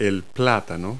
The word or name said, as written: plantain